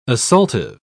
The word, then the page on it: assaultive: British English